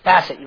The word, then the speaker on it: facet: Yes, I don't know if this word is correct, Michelle, but we are just a faucet in the diamond of God's plan. Facet? Faucet? I don't know how you say it. Facet, you were right. She said, I think you're saying that wrong.